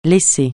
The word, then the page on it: laisser: francais